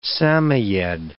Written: Samoyede

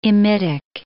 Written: imidic

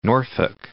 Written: Norfolk

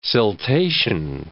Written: siltation

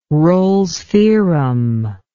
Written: rolles theorem

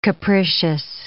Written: capricious

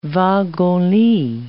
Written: wagon-lit

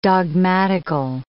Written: dogmatical

